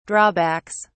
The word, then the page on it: drawbacks: British English